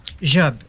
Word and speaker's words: Job: Euh, bon, mais euh, pour commencer, on va faire un, petit parcours sur ta vie, Catherine, parce que euh, on, prononce ton nom Job. Job, oui. Pareil comme euh, Steve Job, mais sauf qu'il s'écrit pas pareil.